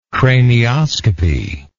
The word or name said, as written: cranioscopist